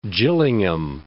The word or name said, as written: Gillingham